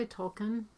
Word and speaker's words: Tolkien: I say Tolkien.